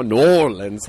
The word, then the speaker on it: New Orleans: New Orleans. How do you say it? – New Orleans.